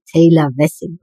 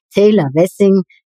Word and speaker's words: Taylor Wessing: So, jetzt sage ich es dir aber richtig. Sie ist ähm, eben Rechtsanwältin bei der internationalen Wirtschaftskanzlei Taylor Wessing. So, jetzt habe ich es richtig ausgesprochen. Taylor Wessing in Düsseldorf.